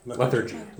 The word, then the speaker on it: lethargy: Lethargy. Am I pronouncing it right? – Lethargy. – Lethargy. Lethargy. Okay. – But you can pronounce it differently. – I'm putting the emphasis on the wrong syllable.